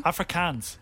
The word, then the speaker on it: Afrikaans: How do you pronounce Africana? – Afrikaans.